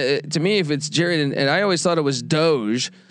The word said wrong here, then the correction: Doege: their starting quarterback, he's a Bowling Green transfer, but I believe it's Jared Deggy. I believe Deggy is the correct way to pronounce that.